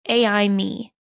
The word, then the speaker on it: AI Me: He named it AI Me, pronounced like Amy.